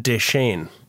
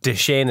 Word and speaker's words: Decheane: My name is Ken McGuire, joined in studio as always by Remington Deschene. But Deschene is spelled D-E-C-H-E-A-N-E. – I'm surprised with your hair colour that it's not S-H-A-Y-N-E.